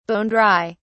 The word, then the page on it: bone dry: British English